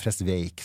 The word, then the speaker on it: Fresvik: Og så kommer Fresvik på, eller Fresveik som du uttaler, så lærte jeg meg, han har lest biografien.